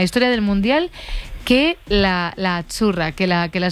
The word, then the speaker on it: Azzurra: Azzurra. – Azzurra. Mira, ¿ves? – Azzurra. Es que... Azzurra. Sí, como porque pizza. La Z no es la Z como decimos en catalán tan sonora, no.